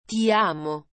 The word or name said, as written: ti amo